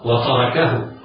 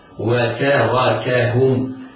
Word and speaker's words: وتاركهم: وتاركهم إياه وإياك، إياك أيها القارئ أن ننطق خطأ وتركهم، لا، وتاركهم في حروف مرققة.